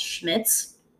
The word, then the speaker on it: Schmidt's: The one that I really like the most is called Schmitz. So it's spelled S-C-H-M-I, D as in dog, T as in Tom, S as in Sam.